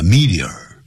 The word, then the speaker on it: meteor: 好这样但是流逝的那种流星呢那么我们已经说过叫 meteor, M-E-T-E-O-R, 有两个发音 meteor 或是 meteor, 两个发音都可以。